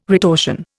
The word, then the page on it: retortion: Indian